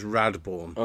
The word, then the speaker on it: Radborn: So I put your name in my phone as Mark Radoborn, as in three syllables. – No, it's just Radborn.